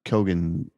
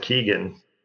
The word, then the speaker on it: Keoghan: I thought he was good, but I enjoyed Barry Keoghan, however he pronounces. – I think you pronounce it Keegan.